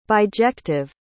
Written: bijective

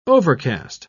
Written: overcast

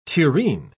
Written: tureen

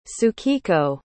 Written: tsukiko

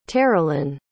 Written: terilynn

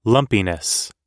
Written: lumpiness

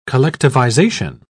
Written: collectivization

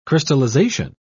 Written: crystallization

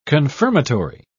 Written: confirmatory

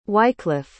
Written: wycliff

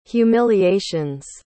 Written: humiliations

